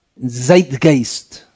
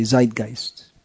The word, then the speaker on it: Zeitgeist: You need a German to pronounce it properly. It is used in English also, that word, but not with proper German pronunciation. In English you just say Zeitgeist, but anyway, it's he's the personification of the spirit of the age, which is just to be totally decadent and nasty.